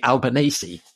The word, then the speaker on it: Albanese: which is Labour under Anthony Albanese. I think I've pronounced that right. – Yeah.